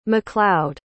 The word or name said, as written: MacLeod